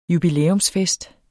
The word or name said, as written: jubilæumsfest